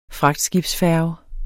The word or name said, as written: fragtskibsfærge